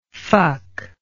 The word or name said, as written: fucky